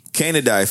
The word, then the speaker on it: Canidae: Let me pronounce it properly. Pronounce it. Oh. It's part of the. Canine, Canidae family, and it's a large group like that includes domestic dogs, wolves, coyotes, fox, jackals, dingoes, and many other extinct dog mammals.